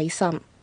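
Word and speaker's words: イサム: わっかいさん、わっかは水、いさん、発音注意してくださいね。むといさむではなくいさん、飲み込むように発音してください。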